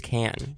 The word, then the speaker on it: Cannes: And it's not Cannes. – Oh. – It's not Cannes. There's a lot of different ways of pronouncing it, but people, it is Cannes.